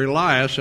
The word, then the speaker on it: Elias: Jesus told us that John was the literal reincarnation of Elijah, or Elias, as it is pronounced in the New Testament, who was taken by the Lord without physical death that you can read about back in your Old Testament.